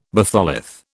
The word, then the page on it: batholith: British English